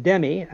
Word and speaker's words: Demme: On the other side, a case called Stewart and Demme, I think that's how you pronounce it, D-E-M-M-E, 2020,